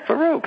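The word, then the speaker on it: Farrokh: Farouk. Farouk, that's how you say it.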